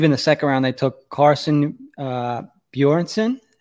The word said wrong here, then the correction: Bjarnason: Let me see, make sure I'm saying his name correctly there. Carson Bjarnason, sorry about that, was their second-round pick, their third overall pick.